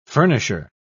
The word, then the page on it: furnisher: British English